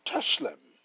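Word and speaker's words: tselem: The word image in Hebrew is pronounced teshlem, meaning illusion, resemblance, hence, representative figure.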